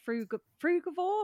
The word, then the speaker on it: frugivore: And I, you know, we're going to be talking about what it means to be a frugivore.